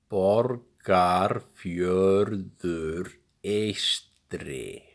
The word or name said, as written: Borgarfjörður Eystri